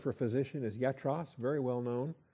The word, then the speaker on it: iatros: Yatros, starts with an I. So you pronounce it with a Y sound, like Jesus and Yaakov and all the other Greek words that start with I. Yatros. I-A-T-R-O-S, used 7 times in the New Testament,